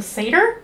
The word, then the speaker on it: satyr: Well, while this is happening, Percy's really close friend and classmate, Grover, kind of accidentally reveals that he is a satyr. Is that how they're pronounced? – Yes.